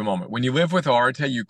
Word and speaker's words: Arete: Arete is how you pronounce the word.